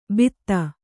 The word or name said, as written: ಭಿತ್ತ